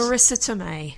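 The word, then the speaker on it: Marisa Tomei: Marissa Tomei.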